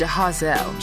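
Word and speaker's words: Jahaziel: Is it Jahazel? – He says it's Jahazel. – Jahazel, featuring Mally Music. This one's called What I Do. I can never pronounce his name. – He pronounces it as Jahazel.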